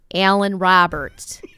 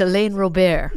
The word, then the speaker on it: Alain Robert: But I'm like, yes, Larry, we are just getting word that this man's name is Alan Roberts and he is from France. And it's Alain Robert.